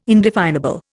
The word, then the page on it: indefinable: Indian